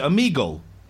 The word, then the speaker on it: Omegle: Is that how you pronounce it? Omegle? – Yeah.